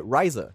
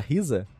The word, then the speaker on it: Ryza: O Atelier Ryza, que eu descobri que é Ryza a pronúncia, eu achava que era Risa, é, ou é Rize.